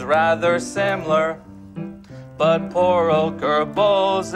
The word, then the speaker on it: Goebbels: excessively deferential to the Germanic pronunciation of Goebbels at the expense of a near rhyme.